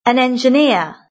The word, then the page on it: an engineer: British English